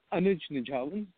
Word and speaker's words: Anuj Nijhawan: The Anuj Nijhawan. The sound in the last name is, I think it's just harder for most speakers of different languages.